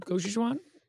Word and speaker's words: gochujang: With vegetables and gochujang.